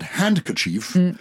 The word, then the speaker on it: handkerchief: It's an interesting one because handkerchief, hanky is H-A-N-K-I-E. – Yes. – But handkerchief. – Kerchief, your hand. – Yeah. But you don't pronounce the D, so it's a silent D.